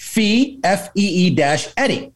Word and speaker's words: Fieri: Fieri is pronounced Fee-Eddie. So Guy Fieri, which is exactly how I was pronouncing it.